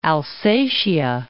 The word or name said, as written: Alsatia